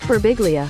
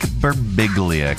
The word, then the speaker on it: Birbiglia: Mike Birbiglia. – Mike Birbiglia.